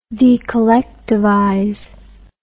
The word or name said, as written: decollectivize